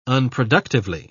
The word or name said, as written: unproductively